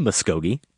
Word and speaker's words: Muskogee: There's only one way to say Muskogee, exactly how it looks. There's even a song, Merle Haggard, I believe, the Okie from Muskogee. So there's no way I would say it incorrectly. And then I got out my iPhone and I went to that part of the show and I heard myself, I think twice, say Muskogee, like Tuskegee. What was I doing? – Just stick with D'Argent.